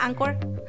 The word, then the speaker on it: Anchor: Anchor.fm, literal así se escribe Anchor, se pronuncia Anchor.